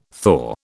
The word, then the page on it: thaw: British English